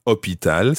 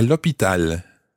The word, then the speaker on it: hôpital: Hôpital starts with a silent H, so it's L apostrophe. J'arrive à l'hôpital.